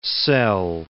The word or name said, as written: Szell